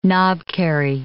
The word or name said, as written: knobkerrie